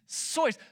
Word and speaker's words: Seuss: It's not Dr. Seuss. The way you say his name is actually Dr. Seuss. It is Seuss. Somebody says sauce. You're wrong. It's Seuss.